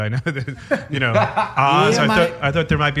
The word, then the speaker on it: AHZ: I did say AHZ on my Instagram, but it is actually Oz.